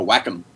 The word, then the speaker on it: Wacom: Yeah, like a Wacom. – Oh, I always forget that that's how it's pronounced.